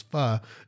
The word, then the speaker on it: pho: I know for a fact that my discipline, today I went to pho, some people call it. I think the correct pronunciation is pho, Vietnamese soup.